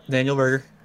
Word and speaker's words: Daniel Berger: Daniel Berger.